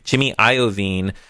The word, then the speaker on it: Iovine: And I apologize last time for totally mutilating his name. I knew the correct pronunciation. I just had this habit of pronouncing it incorrectly. Jimmy Iovine.